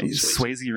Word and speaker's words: Swayze: Yeah, Swayze rhymes with hazy.